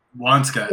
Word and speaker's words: Waniska: Wanishka? – So Wanska. Yeah. So Wanska, it's spelled W-A-N-S-K-A, but, or that's how it's pronounced.